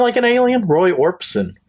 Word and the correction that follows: Orbison: Orbison. – Orbison. – I don't think we're saying it right. I think it's Orbison. Roy Orbison.